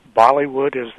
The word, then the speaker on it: Bollywood: The first is, prior to film images and the Hollywood, Bollywood, am I saying that right? – Yeah, right.